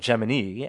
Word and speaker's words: Gemini: And it became very clear that Gemini, or Gemini, as it's pronounced at NASA and in the film, well underrepresented in film and popular media, and something that all three of you really enjoyed.